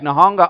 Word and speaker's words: Nihonga: And he's purposeful in his art because what he is teaching, in fact, Nihonga art, that's how you say it properly, but Nihonga art is actually Japanese art written to, done to be distinctive from Western art.